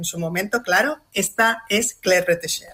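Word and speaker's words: Claire Bretécher: Que nos dejó hace 6 añitos, en 2020, y menos mal que lo pronuncias tú bien, porque ya hemos intentado pronunciarlo en la redacción nosotros todo el día y somos incapaces de pronunciar bien lo de Claire Retesher, pero bueno, ya estamos cerrados para decirlo bien.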